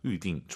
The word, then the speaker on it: 预定: YU 발음이에요. 위 하지 마시고 다시 한번. – 预定。 – 둘다 사성이에요. 검색해 보세요, 여러분. YU로 시작합니다. 위딩.